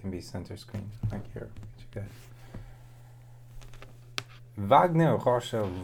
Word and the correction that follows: Rocha: versus Gordon Ryan, and that's how you pronounce it. Gordon. No. Wagner. Wagner Rocha.